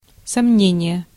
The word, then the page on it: uncertainty: [ʌn.sɜː.tən.ti]